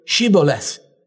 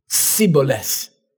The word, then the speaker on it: Shibboleth: They can't make the SH sound. No matter how they try, it comes out as a soft S. So they say to them, say the word Shibboleth. And they say Sibboleth.